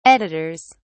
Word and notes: editors: British English

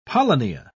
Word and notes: polynia: British English

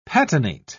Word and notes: patinate: British English

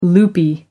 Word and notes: loopy: American English

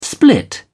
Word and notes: split: British English

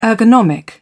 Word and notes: ergonomic: British English

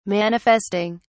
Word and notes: manifesting: British English

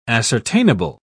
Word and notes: ascertainable: British English

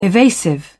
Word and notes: evasive: British English

